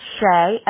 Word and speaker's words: Shea: It's Shay, S-H-E-A.